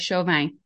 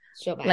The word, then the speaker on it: Chauvin: And I'm feeling a little bit of a French vibe in there. So it's Chauvin. How do you say it, Heather? Say it for us. – Yeah, I would say that, like Chauvin. Chauvin. Like Chauvin. But I don't speak French.